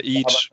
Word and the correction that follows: Aech: Age. Ja, genau. Also A-E-C-H zwar geschrieben, aber ausgesprochen wie das englische H.